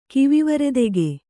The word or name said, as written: ಕಿವಿವರೆದೆಗೆ